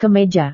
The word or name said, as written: kemeja